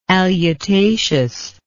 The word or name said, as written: alutaceous